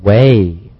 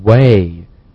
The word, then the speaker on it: weigh: Right, 大家注意了，它和我们原来学到的那个 S-I-G-H-T, 我们说我们的视野，那个单词呢，发音是一样的，但是它的意思是完全不同的两个单词。 – Next we have the word way, way, W-E-I-G-H, way.